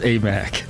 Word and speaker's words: Aimaq: Fardaz Amak.